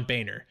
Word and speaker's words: Boehner: john boehner whose name is spelled like boner but it's pronounced Boehner.